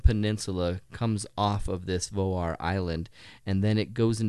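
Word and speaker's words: Vágar: It's spelled V-A-G-A-R. But if anyone asks, if you ask anyone there, it's like Voar. It's like pronounced Voar. Mm. Voar, Voar, Voar. And so it's like not pronounced Vagar.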